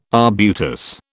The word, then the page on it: arbutus: British English